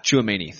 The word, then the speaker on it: Tchouaméni: But I don't think you can count on how do you pronounce his name again? Tchouaméni. – Yeah. – Tchouaméni. Thank you.